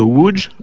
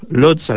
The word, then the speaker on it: Łódź: résolu quand on apprend que le L barré propre à l'écriture polonaise se prononce WE, Karol Wojtyła, ou l'air Wałęsa, ou encore la ville de Łódź, Lodz à la française, patrie du grand pianiste Arthur Rubinstein.